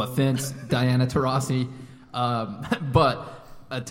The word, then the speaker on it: Taurasi: And then lastly, you guys are going to have to help me with the pronunciation of her name, but Diana Taurasi. – That's right. Nailed it.